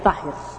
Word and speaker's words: Zahir: En árabe, la voz Zahir significa lo visible o manifiesto, se pronuncia Zahir.